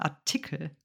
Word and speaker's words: Artikel: Der Akzent Artikel ist tatsächlich hier auf der zweiten Silbe, nicht Artikel, sondern Artikel. Artikel.